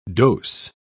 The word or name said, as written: dose